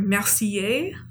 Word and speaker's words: Mercier: The craftsman is Robert Mercier. – Thank you for pronouncing that. – You're welcome. My 1 year of college French does me well.